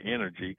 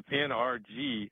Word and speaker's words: NRG: Where people mispronounce it and say energy. – Energy. NRG. – But it was NRG, and they're going when the Houston Stadium signs come down, it's going to go back to Reliant Energy or Reliant Stadium.